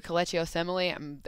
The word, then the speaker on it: Kelechi Osemele: Then they also have, I mean, when you look at their O-line too, Kelechi Osemele, I always butcher his name, but I'm not, and I'm thinking.